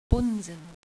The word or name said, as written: Bunsen